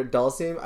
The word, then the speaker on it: Dhalsim: I was wanting fucking Dhalsim. Is that how you say it? – Yeah. – Dhalsim, yeah, he's dope.